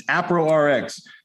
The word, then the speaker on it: AproRx: And say it right. It's not a pro. It's not a Prox. It's AproRx. Everybody knows what that means. That's short for appropriate prescription.